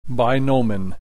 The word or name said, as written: binomen